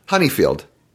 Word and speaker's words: Honeyfield: Honeyfield. – Yes, right. Like it's spelled.